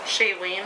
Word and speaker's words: Shailene: I guarantee it's because I keep seeing him and Shailene Woodley. Is that how you say your name? – Shailene.